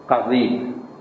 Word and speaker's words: قضیب: ول قذیب، قذیب یعنی آلت ذکوریت حیوانات، اینا حرام خوردنش. بله چی؟ تلفظش قاف و سادزاده، قضیب.